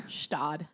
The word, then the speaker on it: Gstaad: How do you say that? Gstaad? – Gstaad. – I call it Goosted.